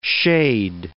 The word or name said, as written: shade